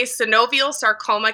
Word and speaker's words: synovial sarcoma: You are a synovial sarcoma cancer survivor.